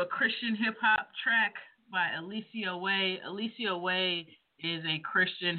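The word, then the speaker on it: Eliseo Way: It's spelled ELISEOWAY, Alicia Way.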